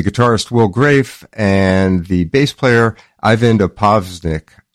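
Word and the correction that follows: Opsvik: I can never pronounce it. Opasvik. That's it.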